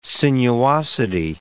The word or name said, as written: sinuosity